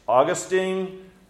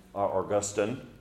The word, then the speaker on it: Augustine: And so when it comes to his being king, guys like Eusebius, Augustine, Augustine, depending on how you like to pronounce his name, John Calvin, sadly, when it comes to him as king, they then jumped too far.